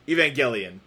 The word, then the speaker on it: Evangelion: Yeah, my impression is that the ending of Evangelion, in both forms of it, Evangelion, I gotta get used to saying it right, Evangelion, my impression is that the ending gets very abstract and weird, sort of almost 2001 A Space Odyssey-like.